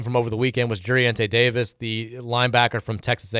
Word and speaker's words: Jurriente: J-U-R-R-I-E-N-T-E. Juriente.